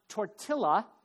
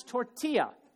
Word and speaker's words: tortilla: It's not tortilla, it's tortilla.